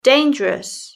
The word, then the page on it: dangerous: British English